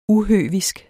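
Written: UHØVISK

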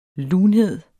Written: lunhed

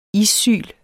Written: issyl

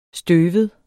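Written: STØVET

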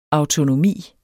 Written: autonomi

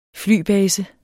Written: flybase

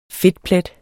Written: fedtplet

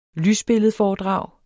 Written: lysbilledforedrag

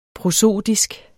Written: prosodisk

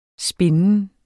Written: spinden

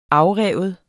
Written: afrive

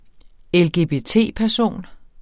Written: LGBT-person

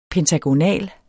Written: pentagonal

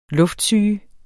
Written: luftsyge

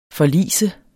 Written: forlis

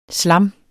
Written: slam

